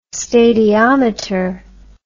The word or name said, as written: stadiometer